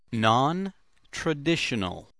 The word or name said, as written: nontraditional